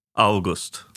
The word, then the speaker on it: August: Men han menar i alla fall att rätt uttal, det är som månaden. – August. – Ja, eller det blir ju det. – Augusti, ja, august. Ja, precis, det blir ju det om man alltid säger augusti.